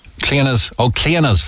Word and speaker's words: Clionas: Call their name out again clearly. – The Cleanest Foundation. – How do you spell that? – C-L-I-O-N-A-S. – Cleanas Foundation. – Yes. – C-L-I-O-N-A-S.